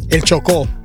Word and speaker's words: Chocó: Pero ahora hay un update de esta información y aparentemente, ¿cómo es que se pronuncia Choco? – El Chocó. – El Chocó. – Sí.